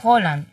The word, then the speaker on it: Haaland: Realmente, discúlpame, es que realmente tienes que pronunciarlo mejor, es Haaland.